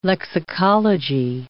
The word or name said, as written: lexicology